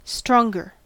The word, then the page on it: stronger: American English